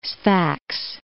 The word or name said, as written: Sfax